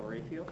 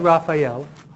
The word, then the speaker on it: Raphael: Okay, it's pronounced, she said Raphael, which is, that's the Spanish, would be Raphael. But in English, we actually say Raphael. Not a big difference, but, you know, Raphael and Raphael is a different pronunciation, right?